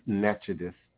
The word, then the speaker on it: Natchitoches: She was a planter, a slave owner, a businesswoman, a colonial Louisiana outpost at the Natchitoches, how we pronounce Natchitoches, and today it has become Natchitoches Parish.